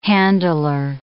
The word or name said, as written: handler